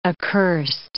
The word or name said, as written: accurst